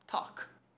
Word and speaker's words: talk: Again, the L is silent. – Talk. – Talk. American talk, British.